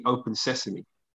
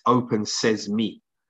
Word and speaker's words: open sesame: It's not actually open sesame. It's open says me.